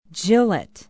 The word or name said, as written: gillett